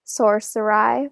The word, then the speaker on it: Sorceri: like they want to kill her um, because like they obviously kill all um, Sorceri. Is that how you say it, Marge? Yeah. but like also she gets saved by Omort, and obviously we know that he is, you know, the ultimate evil.